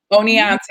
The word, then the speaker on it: Oneonta: Um, uh, am I pronouncing this correctly? Onanchi, Ononcia State University. – Yeah, it's oh Onianta. – Oneonta, mm-hmm. there you go, State mm-hmm.